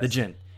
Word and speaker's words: Djinn: Is it The Djinn? Is that how you say it? – That is how you say it. – Yes. The Djinn.